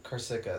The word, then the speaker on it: Corsica: There's the Tyrrhenian Sea, which is between Italy, Sicily, Sardinia, and Corsia. I don't know what that... I can't read that one. Corsia? Corsica.